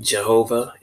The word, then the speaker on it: Jehovah: And we've seen Jehovah, it's with a Y, but it's pronounced with a J, just because that's how the language is.